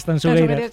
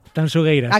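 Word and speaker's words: Tanxugueiras: Tansugueiras. – Sí, esto me lo corrige Fermina, guste mucho. Por favor, digo, mira que yo siempre pronuncio... A ver, otra vez, dímelo. – Tansugueiras.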